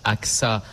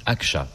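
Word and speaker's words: Ağca: Alors, ça se prononce AXA en français, mais en turc, Akça, puisqu'il était turc.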